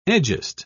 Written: egest

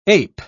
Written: ape

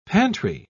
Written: pantry

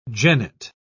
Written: jennet

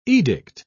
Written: edict

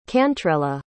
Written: cantrella

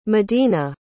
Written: medina

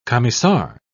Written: commissar

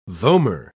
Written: vomer

